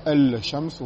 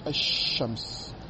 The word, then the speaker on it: الشمس: L شمس on dit H شمس donc le lam ne se prononce pas mais il se prononce comme un deuxième chine avec une chadda.